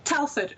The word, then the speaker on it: Telford: Telford, Shropshire.